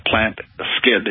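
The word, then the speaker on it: Plantskydd: It's pronounced Plant Skid. It's spelled Plant, S-K-Y-D-D, which is a Swedish word meaning plant protection.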